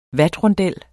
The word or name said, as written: vatrondel